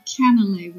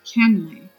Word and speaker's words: canelé: Well, I pronounce it canelé with three syllables, but my girlfriend who's from Paris, she says it's pronounced canelé with two syllables. So I think it depends on where you're from. And both, I think, are correct.